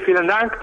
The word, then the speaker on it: vielen Dank: al que le decimos vielen Dank. ¿Lo he dicho bien? – Pues sí, vielen Dank.